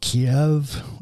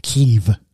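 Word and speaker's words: Kyiv: So it turned out that she has a friend who lives, we still call it Kiev. Ukrainians don't like that because that's the Russian pronunciation. They call it Kiev.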